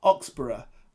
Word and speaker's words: Oxborough: It's pronounced Oxborough, as in Edinburgh or Middlesbrough.